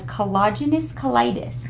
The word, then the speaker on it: collagenous colitis: but it is a new client that has collagenous colitis.